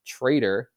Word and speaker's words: TR808R: But yeah, this week he's back and even made the highlights this week with a new package, which is pronounced Traitor, TR808R. So that is super clever for the audio heads out there.